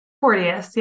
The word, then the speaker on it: Porteus: Porteus, yeah, close.